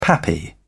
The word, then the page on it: pappy: British English